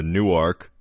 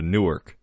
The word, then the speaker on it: Newark: In Delaware, we have a Newark. In Jersey, it's a Newark. – Exactly. – Spelled the same way, pronounced different.